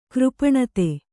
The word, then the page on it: ಕೃಪಣತೆ: křpaṇate